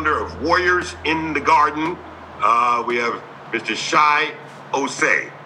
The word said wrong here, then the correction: Chi Osei: Did I pronounce that correctly? – Is he on? – It's Chi Osei. Thank you very much.